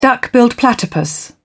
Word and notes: duck-billed platypus: British English

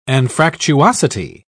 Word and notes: anfractuosity: British English

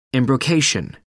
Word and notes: embrocation: American English

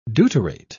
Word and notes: deuterate: British English

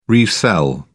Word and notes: resell: British English

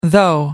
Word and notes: though: American English

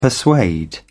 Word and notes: persuade: British English

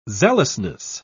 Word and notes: zealousness: British English

